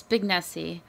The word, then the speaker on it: Spignesi: It's Spignesi.